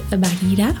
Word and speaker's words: Bagheera: Como Mowgli acaricia a la pantera, que es Bagheera. – Así, así se pronuncia.